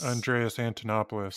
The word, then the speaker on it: Antonopoulos: There was, I'm going to butcher his name, Antonopoulos. – Andreas Antonopoulos.